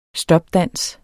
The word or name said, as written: stopdans